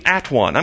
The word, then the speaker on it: atone: And it quickly, very shortly after the word one, became at one. I'm going to go at one them. But it was pronounced atone. Why was it pronounced atone? Because that's Old English pronunciation.